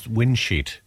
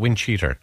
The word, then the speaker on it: windcheater: I always thought it was windsheet. Windsheeter. Not windcheater. So it's wind cheater.